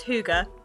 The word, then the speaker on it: hygge: It's pronounced hygge, but it's spelled H-Y-G-G-E.